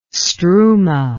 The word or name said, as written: struma